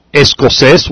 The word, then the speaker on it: escocés: The words that end in S, some of the words that end in S are escocés, which means Scottish. That will carry an accent on the last E, because it ends in S.